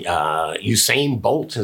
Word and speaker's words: Usain Bolt: And this is just showing Michael Phelps and Usain Bolt.